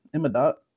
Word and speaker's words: Imavov: Imavov.